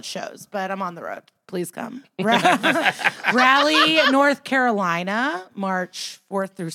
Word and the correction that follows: Raleigh: It is pronounced Raleigh. – Raleigh to the layman. – It's Raleigh. Yeah. I was like, Rally? Yeah. Because I'm from there.